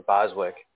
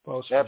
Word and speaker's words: Boseman: Or Boswick. – Yes. – So I always say his last name wrong. – Boseman.